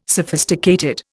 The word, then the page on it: sophisticated: Indian